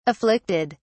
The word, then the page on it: afflicted: British English